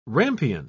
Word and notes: rampion: British English

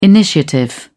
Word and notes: initiative: British English

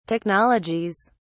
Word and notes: technologies: British English

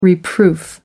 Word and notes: reproof: American English

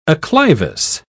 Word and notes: acclivous: British English

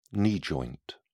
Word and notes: knee joint: British English

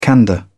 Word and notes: candour: British English

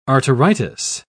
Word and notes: arteritis: British English

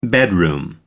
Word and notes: bedroom: American English

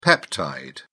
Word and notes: peptide: British English

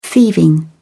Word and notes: thieving: British English

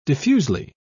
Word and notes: diffusely: British English

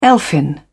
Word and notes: elfin: British English

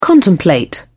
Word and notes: contemplate: British English

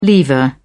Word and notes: lever: British English

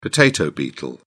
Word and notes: potato beetle: British English

